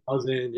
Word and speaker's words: Lausanne: Is it Lausanne Collegiate? – Lausanne, yes, sir.